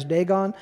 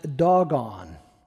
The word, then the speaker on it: Dagon: So it seems that it would be interpreted as Dagon. But if you go into the Strong's Concordance, it's actually pronounced doggone.